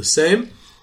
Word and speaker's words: Sejm: Till today, the governing body, the parliament of Poland, is called the Sejm, S-E-J-M, and as in almost all European languages, a J is pronounced as Y.